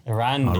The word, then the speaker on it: Arondir: Arondir. – Arondir.